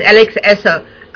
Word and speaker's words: Alex Essos: Now, speaking of other people being a big horror project, what was it like working with the film's lead actress, Alex Essos, if I pronounce it? – Yes, Alex Essos.